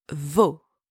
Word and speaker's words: vos: If the noun is plural, whether again it's feminine or masculine, it doesn't matter, you use vos. And same, don't pronounce the S at the end. For example, vos parents, your parents, vos parents.